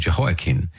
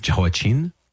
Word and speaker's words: Jehoiakim: I've also heard his name pronounced Jehoiachin with a kind of soft ch, Jehoiachin. But Jehoiakim is how I committed to saying it when we were reading 2 Kings and 2 Chronicles.